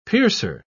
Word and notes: piercer: British English

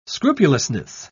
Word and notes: scrupulousness: British English

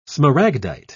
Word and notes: smaragdite: British English

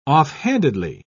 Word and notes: offhandedly: British English

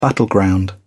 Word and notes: battleground: British English

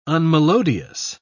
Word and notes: unmelodious: British English

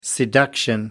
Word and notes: seduction: American English

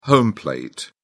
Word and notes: home plate: British English

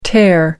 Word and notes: tare: American English

